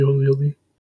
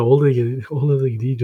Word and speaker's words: OLED: Why are you calling it the OLED? Just call it the OLED. – Because that's how you pronounce it, OLED.